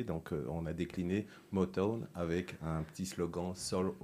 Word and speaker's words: Motown: Motown, prononce bien, s'il te plaît. Motown Oui, presque. Ah, vas-y, redis-nous. Motown. Motown. M-O-T-O-W-N, inspiré du fameux label euh, musical Motown.